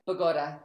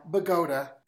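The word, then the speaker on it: Bogotá: Mexico City, Bogota, how do we say that? – Bogota. – Bogota.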